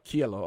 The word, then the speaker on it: Cielo: You know, another favorite song of mine is the closing track, Cielo. Is that how you pronounce it? – Cielo.